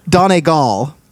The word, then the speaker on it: Donegal: Donegal. Everyone Donegal. Says Donegal. Donegal. Everyone. Whether you're from Donegal or you're from Dublin or you're from Belfast, it's Donegal.